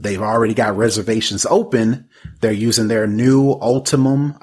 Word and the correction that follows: Ultium: Ultimum Ultium, Ultium, I said it wrong.